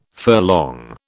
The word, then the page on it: furlong: British English